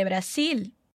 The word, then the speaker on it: Brasil: Muito cuidado com a pronúncia do nome do nosso país, hein? O som de z, z, de abelha, no espanhol não existe. A gente vai pronunciar como na palavrinha acima, si. E o nosso L do final de Brasil vai ficar preso com a sua língua lá atrás dos dentes da frente.